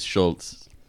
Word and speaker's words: Schulz: I think the pronunciation is the same without the T, though. I think S-C-H-U-L-Z is Schulz.